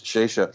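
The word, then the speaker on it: Šešelj: I think it's pronounced Shesha, and described that rally in the course of the book.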